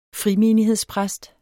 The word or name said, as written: frimenighedspræst